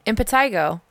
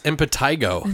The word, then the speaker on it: impetigo: So it's spelled, by the way, guys, is I-M-P-E-T-I-G-O. – It looks like it's pronounced impetigo, but it's pronounced impetigo.